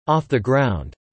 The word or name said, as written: off the ground